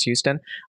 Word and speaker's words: Huston: Anything by Charlie Houston, it's spelled Huston, but I think it's pronounced Houston, he is just a fantastic writer.